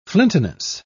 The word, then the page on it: flintiness: British English